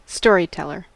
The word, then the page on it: storyteller: American English